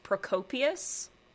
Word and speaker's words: Procopius: So, now there are a lot of contradictory portrayals of Theodora, and they all come from this one dude, and I believe his name is pronounced Procopius.